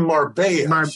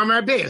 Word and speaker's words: Marbella: And it really goes well with a delicious, crunchy bread because, as I said, the sauce Chicken marbella. – Marbella. See, you're pronouncing it the wrong way. I'm pronouncing it the right way.